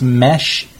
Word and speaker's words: Mesh: A Mr. I believe it's pronounced Mesh, M-E-S-H Hong for the last name.